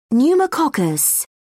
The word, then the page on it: pneumococcus: British English